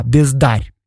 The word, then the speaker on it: бездарь: Или вот он, например, придумал слово «бездарь», правда, с ударением на «а», «бездарь».